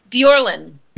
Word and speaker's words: Bjorlin: It's B-J-O-R-L-I-N. – You graduated 4th in your class. – I can't help it. It's like 6th. How do you pronounce her last name? – Bjorlin.